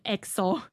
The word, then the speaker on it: EXO: 那这就诞生了 K-pop 历史上的第一个概念世界观玩超大的团 EXO，一不发音 XO。